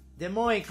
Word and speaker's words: Des Moines: ¿Cómo se pronuncia? – Des Moines, como francés, ¿verdad?